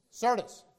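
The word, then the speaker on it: Syrtis: I'm going to not say this right. Anybody want to help me out? Sirtis. Thank you. Sirtis.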